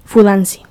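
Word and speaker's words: fudanshi: Bueno, por otro lado está lo que sería los hombres que les gusta este género, que por norma general se les llama fudashi. – Fudansi. – Fudansi, perdón, lo pronuncio mal.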